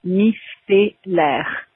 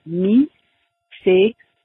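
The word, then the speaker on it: micellaire: And then the second word, most people in French pronounce it micellar. Although I have heard lots of people in English say au micellar, but technically in French it would be micellar.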